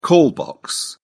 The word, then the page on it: call box: British English